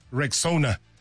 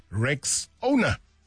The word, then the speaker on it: Rexona: Now, first things first, it's Rexona, just like Rexona. Yeah, say it right and say it proud.